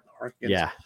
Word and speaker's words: Harkonnens: But he seems to be, at least in part one, to be siding with the Harkonnens more. Am I saying that right? – Harkonnens. Yeah.